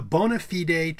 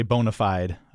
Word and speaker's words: bona fide: The data supports the idea that buying a home with friends is becoming a bona fide trend in the real estate market. – Right. Often we say bona fide. I think. – You can. – Right? – It can be pronounced either way.